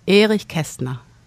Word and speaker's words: Erich Kästner: Erich Kästner.